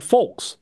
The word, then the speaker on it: folks: What's the deal with the hard L in the word folks? Are the only two people I've ever heard pronounce it with the hard L. Have I and everyone I've ever known been saying it wrong for 42 years? Say that word. – Folks. – So you got no L in there.